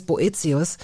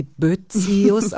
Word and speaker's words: Boetius: Dieses Boetius, ähm, bevor ich es besser wusste, habe ich sie Boetius ausgesprochen. Ja. Sie haben diese kleinen seltenen Punkte über dem E, wenn man will, oft fallen sie weg.